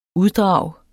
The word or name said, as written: uddrag